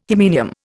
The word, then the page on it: hymenium: Indian